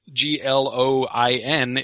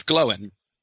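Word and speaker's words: Glóin: Um, and similarly, G L O I N is not gloin, it's glowin. Um, and so that's uh, that's that's the, the primary thing.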